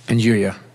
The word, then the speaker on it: nduja: How do you pronounce this? Nduja. – Nduja.